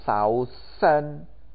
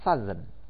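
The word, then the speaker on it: southern: Tetapi cuma gara-gara ditambahkan tiga huruf. E-R-N, E-R-N. Yang mana artinya bagian selatan, membacanya bukan sausen, harusnya tentunya sausen, tapi membacanya sazen, sazen, saz dengan z, sazen.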